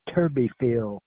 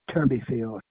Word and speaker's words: Turbyfill: Last name is Turbyfield, spelt with a T. Turbyfield, T-U-R-B-Y-F-I-L-L, Turbyfield.